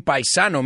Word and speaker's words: paisano: Es un paisano mío, right? And we say paisan, too. We don't say the O. We say it like the Italian way.